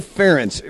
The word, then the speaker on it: Ference: And it's also Ference. It rhymes with parents.